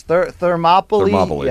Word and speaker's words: Thermopylae: Thermopile or something. – Yes, Thermopylae. Yes, sorry.